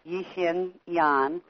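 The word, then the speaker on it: Yi-Hsin Yan: And that her first name is spelled capital Y-I dash capital H-S-I-N. And her last name is Yan, capital Y-A-N, Yixin Yan.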